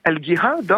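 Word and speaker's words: Al-Jihad (الجهاد): Il va devenir dans les années 70 l'une des figures du mouvement Al-Jihad, hein. Alors c'est le mot jihad mais prononcé à l'égyptienne puisque le J se prononce G dans le dialecte égyptien, et ce mouvement Al-Jihad auquel appartient Zawahiri, il va faire son premier fait d'armes en 1980 avec l'assassinat du président Sadat.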